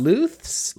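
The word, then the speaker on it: Lusth: I don't know if I know how to say his last name. Ozzy Lust? – Lust. – Luths? – Lust. – Lust. Lust. Ozzy.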